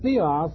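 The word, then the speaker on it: theios: They're both pronounced the same. But notice the slight difference in the spelling. Theos is God, and theos, with an I in here, is divine.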